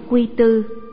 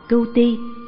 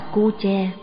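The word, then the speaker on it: Cu Che (龜茲): Tân dịch Quật Chi, Cưu Ti, mà người ta đọc trật vần là Quy Tư, vì họ chỉ biết chữ Quy là rùa, chữ Tư hay chữ Tự là nay mà thôi, chứ họ chẳng biết hai chữ Quy Tư nó còn có vần chính nữa là Cưu Ti, nên đọc là Cu Che đúng hơn.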